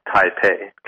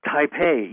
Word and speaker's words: Taipei: Yeah, it's actually the capital of the country of Taiwan, Taipei. – Taipei.